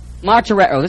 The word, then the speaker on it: martyreo: Martyreo. This is the verb form. M-A-R-T-E-R-O. That's a long O.